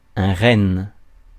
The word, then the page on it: renne: [ɛ̃ ʁɛn]; /ʁɛn/